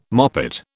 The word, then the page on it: moppet: British English